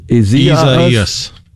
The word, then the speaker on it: Isaias: So an extra syllable in there, it would appear from the way it's typically pronounced, spelled I-S-A-I-A-S. So seems like we kind of insert an extra E in there between the I and the A.